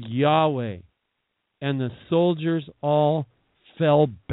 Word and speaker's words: Yahweh: And Yahweh is more of a correct pronunciation of the word Jehovah, who is God. We say Jehovah, but really it's spelled more like Yahweh in the Hebrew.